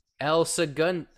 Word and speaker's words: El Segundo: El Segundo, which, funny enough, to tie it back, is the brewery, El Segundo Brewing Company, where Steve Austin's Broken Skull IPA is made.